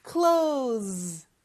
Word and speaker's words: close: Z. 이렇게요. Close, close 이렇게 발음해 주시면 돼요.